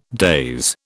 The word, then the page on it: daze: British English